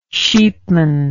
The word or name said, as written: sheepman